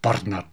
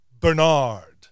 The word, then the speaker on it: Bernard: although there it's actually pronounced Bernard. But in the US, my friends generally give this pretty ordinary name a touch of class by emphasizing the second syllable to become Stephen. Go.